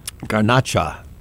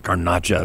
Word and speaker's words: Garnacha: Garnacha, or the Grenache. I always preferred the Spanish pronunciation of it. Garnacha.